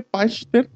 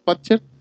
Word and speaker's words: Pachter: la Michael, ¿cómo se dice? Pachter. Pachter. Nosotros le decimos Patcher.